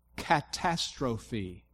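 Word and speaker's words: Catastrophe (καταστροφή): Let me pronounce the Greek word translated ruin here. It starts with a K, but here's the pronunciation. Catastrophe. So where do you think our word catastrophe comes from?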